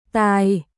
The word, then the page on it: Tài: Vietnamese